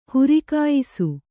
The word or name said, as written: ಹುರಿಕಾಯಿಸು